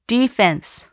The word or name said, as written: defense